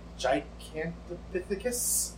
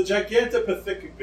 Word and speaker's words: Gigantopithecus: He rhymes it at one point. It rhymes with ridiculous. I know that much. Gigantopithecus? – Sounds good to me.